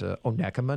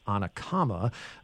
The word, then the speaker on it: Onekama: The treasurer there confirmed that it is pronounced Onekama, not Onekama.